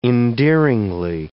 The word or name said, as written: endearingly